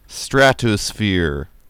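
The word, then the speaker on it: Stratosfear: Like I said, that was Tangerine Dream off their album Stratosphere, with the last syllable spelled like you're afraid.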